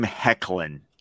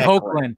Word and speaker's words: Hoechlin: How you pronounce his last name. – I always say Hoechlin. I always say Hoechlin. – It's Hecklin.